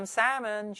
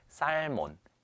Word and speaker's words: salmon: Salmon이라고 읽는데요. 실제로 우리나라에서 많은 학생들과 많은 성인들이 살몬, 셀몬 혹은 뭐 살몬 이렇게 읽으십니다. 사실 외국인한테 가서 살몬 아무리 또박또박 살몬, 셀몬이라고 말해도 이게 연어라는 것을 이해하지를 못합니다.